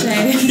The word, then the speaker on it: cache: Because most people can't even say, like, don't even pronounce it correctly. That's just my personal opinion. – Cache.